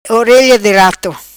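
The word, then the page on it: Orelha Di Rato: patua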